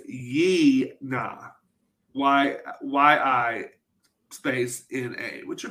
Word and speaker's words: Yina: I watched it on Shudder, and Shudder had I-N-A, and the Google sheet has I-N-A. – Oh, well, IMDb has Y-I-N-A, and that's how they pronounced it, too, was Yi-na.